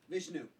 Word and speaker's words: Vishnu: Krishna is an incarnation of the god Vish, Vishnu. Vishnu.